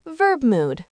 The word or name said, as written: verb mood